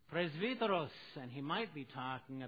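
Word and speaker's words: presbyteros (πρεσβύτερος): By the modern pronunciation in Greek, it's a presbyteros. Okay? You know, we're called Reformed Presbyterians. It's because it's from the same word, presbyteros, or presbyteros.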